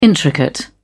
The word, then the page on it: intricate: British English